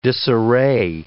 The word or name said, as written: disarray